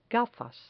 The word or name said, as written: gafas